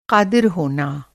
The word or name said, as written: क़ादिर